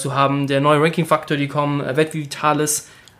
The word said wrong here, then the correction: Web Vitals: Habe hab ich es richtig ausgesprochen? Web Vitalis? Web Vitalis. – Vitals. Oh. Web Vitals.